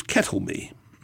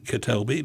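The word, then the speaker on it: Ketèlbey: Actually, I heard somebody refer to him as Kettleby. I'm not sure. There is an accent on the second D, so it should be Ketelby, but Kettleby sounds rather good, I think.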